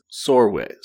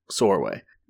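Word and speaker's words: Csorwe: Our main character is Sorway. It rhymes with doorway. It's C-S-O-R-W-E, but rhymes with doorway, Sorway.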